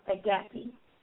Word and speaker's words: agape: And I'm sorry, the word is pronounced agape.